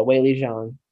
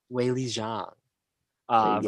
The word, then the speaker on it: Weili Zhang: How is that pronounced? – Yeah, Weili Zhang. – Weili Zhang, versus Rose Namajunas.